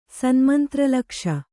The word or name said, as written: ಸನ್ಮಂತ್ರಲಕ್ಷ